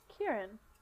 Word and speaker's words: Kieron: What, how's it spelled? – K-I-E-R-O-N. – Yeah, Kieran.